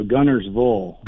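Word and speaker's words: Guntersville: No, Gunnersville. – Gunnersville. Sorry. – Yeah, there's no T in it. Instead of Ville, is V-U-L-L. It's spelled out G-U-N-N-E-R-V-U-L-L, Gunnersville.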